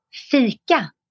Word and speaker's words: fika: It's fika. – Ooh. – And it's spelled F-I-K-A.